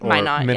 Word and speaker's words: Minot: Minot.